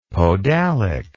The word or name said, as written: podalic